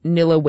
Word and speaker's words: Nilla: I think 2021, you impersonated Nilla Wafers. Is that how you pronounce the name of that cookie company? – Yeah.